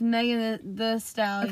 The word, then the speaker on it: Megan Thee Stallion: Is it The Stallion or The? Do you know? – It's Megan the Stallion. – Okay, so they don't... But it's spelled like The. She does, so she doesn't say The. – What is the difference of it, The and The? – It's... She spells it with two E's.